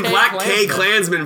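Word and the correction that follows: BlacKkKlansman: It's not Sterling K. Brown. It's Black Klansman. – He turns to the camera and he goes, K is silent.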